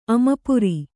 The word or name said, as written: ಅಮಪುರಿ